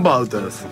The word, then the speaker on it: Wauters: Me olvidaste cómo pronunciarme el nombre ya. – Ah, perdoname. ¿Cómo era? – Bouters. – Juan Bouters.